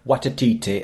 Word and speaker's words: Waititi: Waititi, as the guy pronounced it on that video.